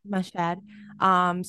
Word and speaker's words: Mashhad: Am I saying that correctly? – Mashhad.